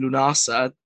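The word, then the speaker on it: Lughnasadh: Again, link back to our lambs and loaves with Lunasa. I remember the first time looking at that thinking, no, I can't pronounce that. Why is that D and a H silent? Why is it not pronounced?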